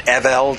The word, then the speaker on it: Evil: Evil, or I prefer to pronounce it Evel, just to make sure it's... I don't want people to think that evil was a description.